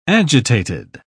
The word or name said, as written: agitated